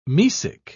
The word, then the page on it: mesic: British English